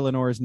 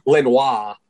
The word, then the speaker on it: Lenoir: Dan Fouts had no idea how to pronounce Diamador Lenore's name. Oh, big player from the Ducks. Lenore. – Dumbledore. – Literally. – Dumbledore Lenore. – Lenoir. – Dumbledore Lenoir.